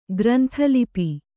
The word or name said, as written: ಗ್ರಂಥಲಿಪಿ